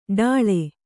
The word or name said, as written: ಡಾಳ